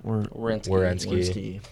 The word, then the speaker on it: Werenski: And how do you say his last name? Zach Werenski.